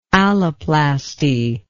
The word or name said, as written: alloplasty